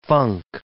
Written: funk

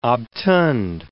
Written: obtund

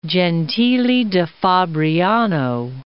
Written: Gentile da Fabriano

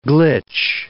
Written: glitch

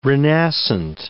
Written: renascent

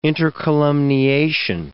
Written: intercolumniation

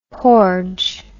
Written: porge

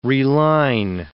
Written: reline

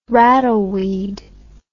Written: rattleweed

